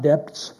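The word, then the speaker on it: debt: It has a B, but the B is not pronounced. It's not debts, it's debts, like D-E-T.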